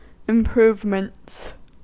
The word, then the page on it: improvements: American English